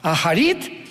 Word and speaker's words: Acharit: que son las palabras Aharit Shanek en el hebreo. Se escribe Acharit, A-C-H, tal como suena.